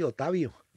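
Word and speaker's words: Otávio: Pero es que se dice así, porque allá está en portugués así, Otavio. Sin sí.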